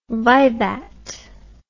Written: vivat

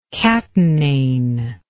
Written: catenane

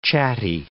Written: chatty

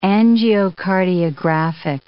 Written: angiocardiographic

